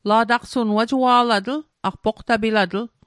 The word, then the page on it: wajua'latl: Mi'gmaq